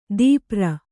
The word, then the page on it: ದೀಪ್ರ: dīpra